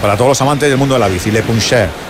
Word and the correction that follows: Le Puncheur: ¿Cómo se escribe Le Puncheur. – Le Puncheur. – Eso es.